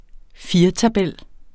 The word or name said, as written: firetabel